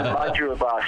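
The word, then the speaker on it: Mudgeeraba: It's a tongue twister. I'll have to tell you. Mudgee Bar Show. Don't feel bad. If you don't live up on the Gold Coast, you never know how to pronounce that.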